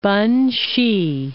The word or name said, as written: Benxi